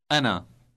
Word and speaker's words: أنا: Anna.